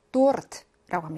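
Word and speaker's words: 도르트 (торт): 케이크, 러시아인들이 굉장히 좋아하는 디저트인데, 러시아어로 도르트라고 합니다. 르르 또르할때 굴러야 됩니다.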